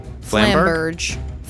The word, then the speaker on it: Flamberge: I say Flamberg, it might be Flamberg. Flumbag. Flambag. Only you can hear it. We can't hear it. – They're saying with the most American pronunciation ever. – I don't care. Flamberg. – Flamberge. – Yeah, Flamberge. I love so Flamberge. Oh, that's so much better.